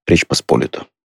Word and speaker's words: Речь Посполитая: то ударение ставится по-другому. Речь Посполитая.